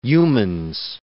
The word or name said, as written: Youmans